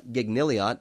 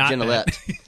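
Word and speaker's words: Gignilliat: Mark Gennelet, or it looks like Gignilliot, but just so readers know, it's pronounced Gennelet.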